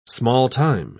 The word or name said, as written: small-time